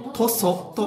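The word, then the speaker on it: 屠蘇: 漢字ではほふり、よみがえると書いてとそと読みます。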